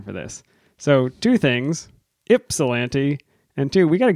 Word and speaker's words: Ypsilanti: What I pronounced as Ypsilanti, Michigan. – Ypsilanti. – I was wrong. – We put out a call for somebody to please correct us. We do care about the words we use. So I got an email this week. It said, "Hey guys, thanks so much. We listened to your last episode specifically about the brewer from Ypsilanti, Michigan, pronounced Ypsilanti. It's no bother, though. Everyone pronounces it incorrectly, even Michiganders.